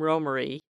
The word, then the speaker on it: Romare: Actually, to his friends, he was Romy. But he pronounced his name Romare, although many people, even those in his own family, pronounced it Romare, which is how we're most used to hearing it.